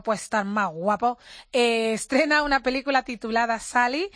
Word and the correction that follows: Sully: que tiene el que se escribe Sully. – Sully, efectivamente Sully, que